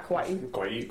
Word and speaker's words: Kauai: We also went to Kauai, I think that's how you pronounce it. – Yeah, Kauai.